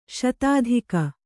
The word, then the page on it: ಶತಾಧಿಕ: Kannada